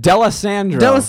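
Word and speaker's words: D'Alessandro: How do you say it? – DeLisandro. Fuck, Joe. – I tried to make it cool and like Italian, but I couldn't. – I don't say it right. I just brutally New Yorked it.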